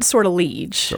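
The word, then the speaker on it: Sortilège: They pronounce it Sortilege. – Okay, Sortilege. – But that's not how it would be pronounced in French.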